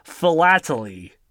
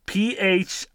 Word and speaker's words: philately: There is like the study of stamps and postal materials is called, oh God, how do you pronounce this? Philately. Philately.